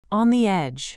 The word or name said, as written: on the edge